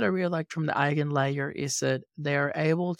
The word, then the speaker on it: EigenLayer: I am not entirely sure how to pronounce it, but it looks, and I think it sounds like Eigenlayer. – I believe that's the correct pronunciation, yes.